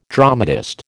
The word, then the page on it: dramatist: American English